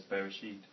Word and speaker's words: Bereshit: I've sounded as Bereshit.